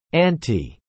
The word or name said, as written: anti